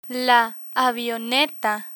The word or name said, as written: avioneta